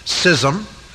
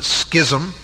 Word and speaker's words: schism: Now, what we demonstrated last week in our study, primarily introductory, was the fact that there is a proper and right schism, or some people pronounce it schism, in the church.